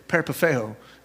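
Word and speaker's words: Peripateo (περιπατέω): It's para, para, it's, it sounds like peeling a potato. Peripateo is how you say it in, in the Greek, and it literally means citizenship.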